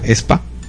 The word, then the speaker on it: aespa: El primero, el grupo no se llama AESPA, de hecho se llama ESPA. – ESPA, sí, o sea, se pronuncia así, o sea, ESPA. – Se escribe AESPA y se pronuncia ESPA.